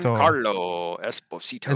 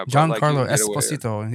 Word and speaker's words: Giancarlo Esposito: It's Giancarlo Esposito. – Yeah, Esposito. Giancarlo Esposito.